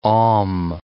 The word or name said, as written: Om'